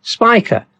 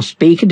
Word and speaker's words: Spica: Now, the next morning, the moon will accompany Spica, or speaker, depending on how you want to pronounce it.